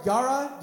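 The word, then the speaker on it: yara (יָרֵא): The fear of the Lord, Yara, Yahavah. Fear the Lord. This is what it means. Fear is pronounced yara as a verb in the Hebrew, which means to respect, to revere, to have reverence for the Lord.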